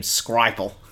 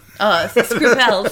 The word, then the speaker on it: Skripal: So this guy who probably does not pronounce his name, Skripal. – Skripal, sorry.